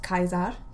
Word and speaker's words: Caesar: Anyway, she was telling me about how that famous line we all know from Caesar, who would actually be pronounced Kaiser, but whatever. The C is a hard C.